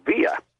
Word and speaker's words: villa: Like, for instance, V-I-L-L-A, it's pronounced Villa any place except down in South, in Argentina, it's Villa.